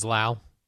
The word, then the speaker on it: Lowe: They spell their name the same way, L-O-W-E, and we pronounce it differently. – One's Lowe, one's Lau.